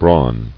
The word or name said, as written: Brawn